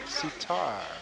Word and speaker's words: sitar: So it's Bob Curtis and Paul Marcus and Evelyn and Tim Taylor on guitars, keyboards, harmonica, accordion, recorder, tambourine, and electric sitar. – Electric sitar? – Yeah. – Sitar. Nice. – Yeah, you know that. Sitar rhymes with guitar, you know that?